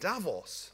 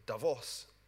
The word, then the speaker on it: Davos: Once people at Davos, or Davos as it should be pronounced, at the World Economic Forum start talking about secular stagnation, you should start positioning yourself for global recovery.